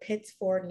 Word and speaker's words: Pittsford: I'm going to say it like phonetically, not Pittsburgh, but Pittsford, New York.